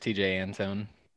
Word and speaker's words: Antone: And that's with a blown save today by TJ Antone. Is that how you pronounce it? – I think it's just TJ Antone. – Antone.